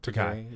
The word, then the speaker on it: Takei: And it's just kind of funny that, you know, George Takei, how do you say it? – Takei, either way.